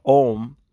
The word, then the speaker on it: Om: They call it the Om, the O-M. And then it can be pronounced A-U-M, right? If you're actually using each sound in the Om. So it's A-U-M, it's a chant. It's you'll hear a lot of monks saying it sometimes, Om, right? But if said correctly, it's actually supposed to be four syllables.